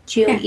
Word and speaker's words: Goethe: How do you say his name? – Goethe.